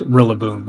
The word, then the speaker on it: Rillaboom: We all agree that Rillaboom is pronounced Rillaboom, right? – Right.